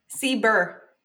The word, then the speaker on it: CBER: Did you say CBER? – CBER. – How do you spell it? – C-B-E-R.